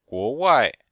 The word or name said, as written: guo2wai4